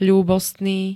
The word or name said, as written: ľúbostný